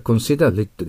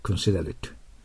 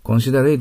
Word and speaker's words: considerate: これは considerate ですから、思いやりのある、思慮深いという形容詞です。発音してください。 considerate。 considerate じゃないんですよ。 considerate。